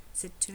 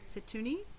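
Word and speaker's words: Msituni: It's M S I T U N I. It's a lot of vowels. I would just say you wouldn't pronounce the M. Setuni. – Oh yeah, true. – Setuni.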